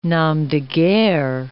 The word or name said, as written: nom de guerre